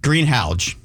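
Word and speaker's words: Greenhalgh: It's not even close, but people get greenhouse or green hall out of H-A-L-G-H. – And it's pronounced? – Greenhouge. – Greenhouge. – Yeah, I always tell people the name Hal. You know, green, the color green, the name Hal, and the GH is like the J in jar, Greenhouge.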